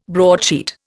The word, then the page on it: broadsheet: Indian